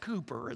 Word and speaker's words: Cowper: Now, if you're looking at the screen, you're saying, wait a minute, that's William Cowper. No, it's how it's pronounced. Cooper is the correct pronunciation.